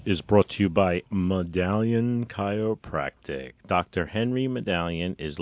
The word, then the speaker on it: Madalian: M A D A L I A N Madalian Chiropractic.